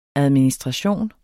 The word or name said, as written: administration